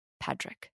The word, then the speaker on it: Padraic: Especially if it's spelled like in the Irish way. P-A-D-R-A-I-C. Patrick.